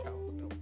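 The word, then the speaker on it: Zhao: Creo que sí es Shao. Sí, digamos que sí. Sí, porque pues, es, es, China, güey. Entonces, sé sé se pronuncia Shao bueno,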